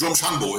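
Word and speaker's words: Drumshanbo: Es heißt nicht The Shed Whisky, es heißt, wie heißt es genau ausgesprochen? – Ist Drumshambo, ist benannt nach dem Ort, wo sich die Distillerie befindet.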